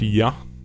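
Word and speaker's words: Ya (Я): which is actually pronounced Ya, uh, which means I.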